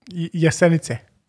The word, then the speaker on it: Jesenice: So you were originally from, I'm going to try to pronounce this, Jesenice, Slovenia. – Jesenice. – I was pretty close. – You got a little Z in there.